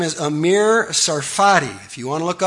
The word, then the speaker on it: Sarfati: A-M-I-R, his first name, Amir Sarfati, silent T, S-A-R-F-A-T-I.